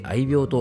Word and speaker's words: 愛猫: はい、えー、前回配信の愛愛猫と書いて愛病と読むなんて勉強になりました。